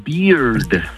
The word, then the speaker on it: beard: Porque barba en inglés se pronuncia beard, termina con D al final.